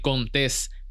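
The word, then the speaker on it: Contés: Mi nombre es Miguel Contes con acento en la E.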